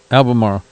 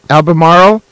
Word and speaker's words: Albemarle: It says, shout out to Brother Joe Edwards and Pasteurizer Brian in Albemarle. – Yeah, Albemarle. – Did I say that right? – Yep. – Albemarle.